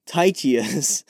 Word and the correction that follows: Tychicus: When I really study that portion of Ephesians, I'll get his name right. But we're going with Tychius. Oh gosh, Tychicus.